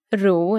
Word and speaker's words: ro: So, pronounced Ro in Swedish, it's just another word.